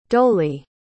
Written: dolaidh

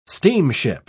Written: steamship

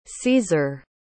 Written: caesar

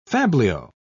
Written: fabliau

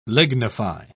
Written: lignify

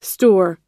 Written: stour

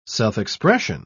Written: self-expression

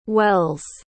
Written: welles

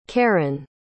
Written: caron

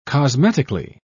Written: cosmetically

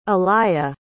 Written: alaya

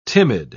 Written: timid

